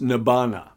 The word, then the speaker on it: Nibbana: In the Pali language, it is pronounced Nibbana.